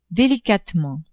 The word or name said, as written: delicatement